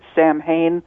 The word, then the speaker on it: Samhain: S-A-M-H-A-I-N. So if you pronounce it Samhain, really off on that. Its pronunciation is Samhain.